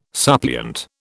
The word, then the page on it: suppliant: British English